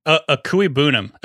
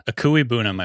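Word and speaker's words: Okwuegbunam: And then I cannot pronounce his backup's name. Albert. – People just call him Albert O. – Akui Bunim. Akui Bunim, I believe is how you say it.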